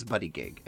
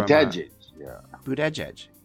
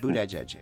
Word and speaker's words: Buttigieg: Well, I thought it was Buttigieg, and I thought, what a great name for a gay man, right? Buttigieg. But it's not. It is... Oh, here it is. Pete. It's pronounced, and I'm going to go through a list of these. Pete... Buttigieg. He's from Buttigieg. – He said Buttigieg.